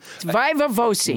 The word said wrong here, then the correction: viva voce: Because listener Christian Brady posted on Facebook that the word is pronounced viva voce.